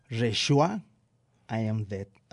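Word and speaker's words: Rejoice! I'm Dead!: Estas dos cosas simboliza el fantástico disco nuevo de Gong, Reshua, I Am Dead. Así se pronuncia, Saúl, según tú.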